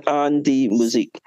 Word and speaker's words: An Die Musik: and the D-I-E only pronounced the letter D has the German. The I-E just disappears. So it actually say has an, A-N is an, D-I-E is pronounced as, say has D, and M-U-S-I-K has music. So it's An Die Musik.